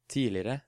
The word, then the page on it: tidligere: Norwegian